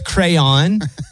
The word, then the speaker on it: crayon: A crown. – I'll accept it. You said crown, but it's crayon.